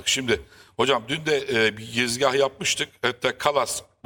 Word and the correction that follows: Kallas: bu hanımefendinin ismini çok telaffuz edememiştim. Kalas. Yani kadın Kallas. – İki tane Leyli hocam. Yani, Kallas.